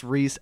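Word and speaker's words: Reiss: I think it's Reese, but I would pronounce it Rice, but I'm pretty sure they pronounce it Reese in the show. Just the way it's spelled makes me think it's Rice.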